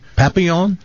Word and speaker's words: Papillon: And for people that are fans of Steve McQueen, Papillon? – You know, it's a French name, so it's pronounced Papillon.